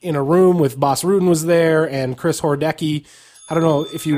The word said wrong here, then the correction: Horodesky: He mispronounced it's Horodesky. – Horodesky.